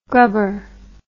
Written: grubber

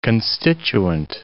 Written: constituent

